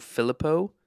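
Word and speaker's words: Philippou: Filippo, did I say that right? – Yep.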